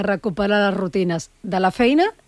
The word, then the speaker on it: feina: Pues lo primero, ser consciente de esto, de la feina i de la escola. ¿Lo he dicho bien? – Feina, quasi. – Feina. – Feina, molt bé.